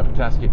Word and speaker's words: Petoskey: Yeah, Petoskey.